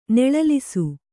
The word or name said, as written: ನೆಳಲಿಸು